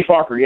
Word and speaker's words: Farquhar: And it made me feel bad for this guy's last name, Farquhar? Is that how you say it? Danny Farquhar? – Danny Farquhar, yeah.